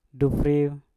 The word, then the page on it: dufriu: Mpur